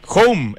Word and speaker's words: Houm: Home, esto es H-O-U-M, para los que no los conocen, se dice igual H-O-M, que Home, pero se escribe Home.